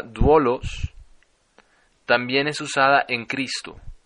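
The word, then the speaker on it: duolos (δοῦλος): La palabra se pronuncia duolos, primero duo, D-O-U, y la siguiente letra aparece una Y invertida, llamada lambda. Se trata de una I y termina en os, O-S. Se pronuncia duolos y significa esclavo o sirviente.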